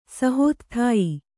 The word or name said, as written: ಸಹೋತ್ಥಾಯಿ